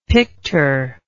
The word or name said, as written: pictor